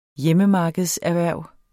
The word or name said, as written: hjemmemarkedserhverv